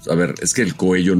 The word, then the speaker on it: Coello: ¿Por qué se pronuncia Coello y no Coelho, güey? – No, pendejo, porque la doble L siempre suena como Y en castellano. No sé si lo tengas claro. En castellano.